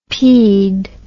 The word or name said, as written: pd